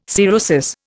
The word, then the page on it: cirrhosis: Indian